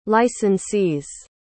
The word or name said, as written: licensees